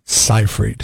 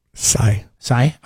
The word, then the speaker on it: Seyfried: I don't know how to say her last name. – Seyfried. – Seyfried? Amanda Seyfried or Seyfried? – Sai.